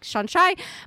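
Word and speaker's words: Seanchaí: Is it like It's spelled Shan-Chai. Like, it's actually the name, like, Sean tea. And then Chai Tea. So it's like Shan-Chai, but a shape-shifting collector of lore.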